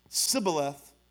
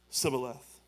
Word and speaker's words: Shibboleth: Shibboleth was wrong.